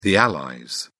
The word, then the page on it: allies: British English